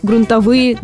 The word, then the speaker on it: грунтовые: Например, дождевые воды размыли грунтовые дороги. Что характерно, и в слове «дождевые», и в слове «грунтовые» ударение на предпоследний слог.